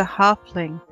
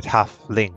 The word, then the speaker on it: halfling: So he was a halfling, not a halved man. – Is that how you say it? Halfling? – Yes.